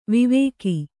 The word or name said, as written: ವಿವಾಕ